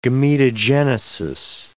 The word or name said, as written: gametogenesis